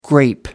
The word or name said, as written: grape